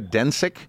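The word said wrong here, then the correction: Dončić: out of Slovenia. – Luka Doncic. – Doncic.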